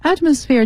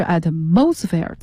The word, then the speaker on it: atmosphere: atmosphere 这个词呢其实更多的是要注意到读音，大气层。有的同学认为是 atmosphere，错的，正确的读音是 atmosphere。好，那么这句话呢，就即使是耕种方法中微小的变化，也能很大程度上减少大气中的碳含量。